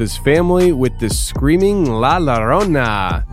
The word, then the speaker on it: La Llorona: It's La Llorona.